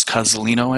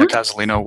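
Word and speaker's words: Cozolino: Thomas Cozzolino, I think is how you say his name. – Uh-huh. – Yeah, Cozzolino, Why Therapy Works.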